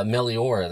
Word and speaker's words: Meliora: How do you say the name of the Ghost album? I don't want to, like, screw it up. – Oh, Meliora. That's how I've heard it, you know.